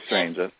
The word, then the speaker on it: Stranges: Am I pronouncing that correctly? – Yeah, Dr. Stranges.